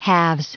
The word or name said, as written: halves